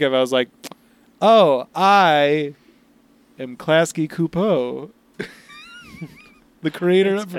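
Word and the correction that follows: Csupo: It's pronounced Klasky Chupo. – Oh, Chupo.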